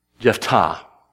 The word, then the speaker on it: Jephthah: I think I'm going to choose to put the accent where the Hebrew puts it, and I'm going to call him Jephthah.